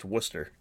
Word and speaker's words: Worcester: Worcester, it's it's Worcester, Massachusetts. It's actually pronounced Worcester. Yeah.